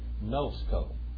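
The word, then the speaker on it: gnosko (γινώσκω): Now, right here, if you really pronounce that correctly, it would be gnosko.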